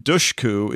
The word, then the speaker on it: Dushku: Now, it also turns out that the first U in Dushku is pronounced like the U in the words push or put rather than the OU in the word for feminine hygiene product, which is the way I'd been pronouncing it.